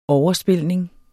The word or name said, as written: overspilning